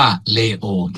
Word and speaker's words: เลโอ: สมเด็จพระสันตะปาปาเลโอที่ 14 ออกเสียงสำเนียงอิตาเลียนนะครับนั้นฝ่ายไทยก็จะออกเสียงว่าเลโอ